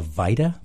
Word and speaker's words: VITA: Am I pronouncing this correctly, the VITA program? – That is.